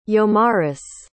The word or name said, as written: yomaris